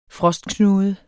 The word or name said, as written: frostknude